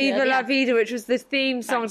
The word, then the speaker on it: Viva la Vida: Diva la vida, da diva, da, I always say it wrong. – Viva la vida.